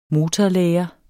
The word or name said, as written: motorlære